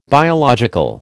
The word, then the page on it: biological: American English